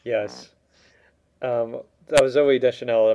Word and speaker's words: Deschanel: Oh, Zoe Deschanel.